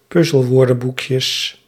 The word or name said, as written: puzzelwoordenboekjes